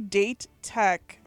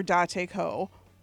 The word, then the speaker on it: Dateko: They say date tech for Dateko.